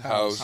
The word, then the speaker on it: Howes: Mr. Hose. – Hose. H-O-W-E-S. Hose. – Hose.